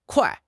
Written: kuai4

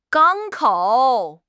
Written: 港口

